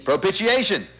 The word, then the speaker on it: propitiation: Whom God has set forth to be the, now notice this, everybody pronounce this with me, propitiation. He's a propitiation.